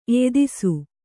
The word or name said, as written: ಎಯ್ದಿಸು